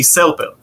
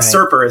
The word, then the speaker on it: Serper: The proper pronunciation in Hebrew for me would be Selpil. – Yeah, Selpil. – But right. Serper is how I.